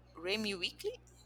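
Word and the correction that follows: Remi Weekes: Gente, como é que pronuncia isso aqui? Remy Wicks.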